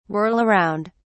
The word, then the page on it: whirl around: British English